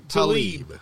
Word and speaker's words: Tlaib: And then Tlaib, is I'm saying her name right? She's a lady who Rashida Tlaib.